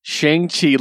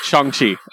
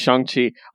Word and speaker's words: Shang-Chi: Or Shang-Chi, I should say. – Uh, yeah. – Uh, he actually corrected the pronunciation on Twitter, and I respect that. – So it's Shang-Chi.